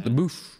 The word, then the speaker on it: LaBeouf: Shia LaBeouf. Is that how I say his name? – LaBeouf.